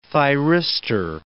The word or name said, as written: thyristor